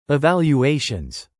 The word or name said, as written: evaluations